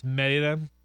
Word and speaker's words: Mérida: Yeah, and it's spelled Mérida, but it's Mérida for whatever reason, because that's how it sounded. – That's how you say it in the native tongue.